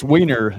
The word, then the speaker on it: Weiner: It's Weiner.